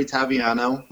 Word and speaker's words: Taviano: It's just my name at Jeffrey Taviano.